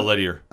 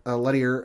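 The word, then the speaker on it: Leterrier: I'm going to butcher this name, so I apologize. Louis Lettier, L-E-T. – Yeah, Lettier. – Lettier,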